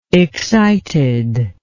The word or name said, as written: excited